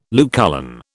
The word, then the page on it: Lucullan: British English